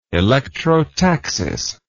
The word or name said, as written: electrotaxis